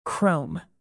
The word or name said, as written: chrome